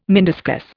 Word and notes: meniscus: Indian

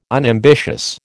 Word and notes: unambitious: American English

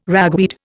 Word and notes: ragweed: Indian

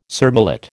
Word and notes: surmullet: American English

